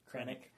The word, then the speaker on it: Krennic: The other two chilling scenes, or scenes I really enjoyed, was Ben Mendelsohn, director Finnick, what'd you say his name? – Krennic. – Krennic, yeah.